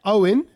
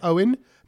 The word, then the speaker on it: Eoin: And this one who's spotted it and he's got it on video is, it says veteran Nessie watcher Owen O'Fudigan is what his name is. – What's his name? – Owen. – Yeah. – E-O-I-N. Yeah. Owen.